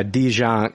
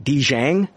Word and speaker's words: Dijiang: but he's actually a, a Dijon. It's pronounced I think Dijiang, and um, it's a, a chaos creature from Chinese mythology and um, they look different in some drawings and things like that.